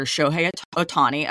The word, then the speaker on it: Shohei Ohtani: So it was involving the former interpreter for the Japanese Dodgers player, Shohei Ohtani. I'm sorry if I'm mispronouncing that. – No, that's correct.